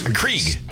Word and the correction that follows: Keurig: Keurig. – Keurig. – Keurig. Yeah. You don't even know how to say it.